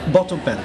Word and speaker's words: Batabyal: That's how I've been told to pronounce my name. In Bengali it's different, it's in English it's completely different. – What is it in Bengali? – It's Batabyal. – Batabyal. – Yeah. Yes, you got it, yes.